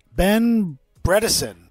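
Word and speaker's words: Ben Bredeson: And then, obviously, the second trade with the Ravens for, we're going to get this right, Ben Bredesen. That's how you say his name.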